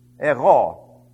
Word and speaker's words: ego: Ego. E-G-O.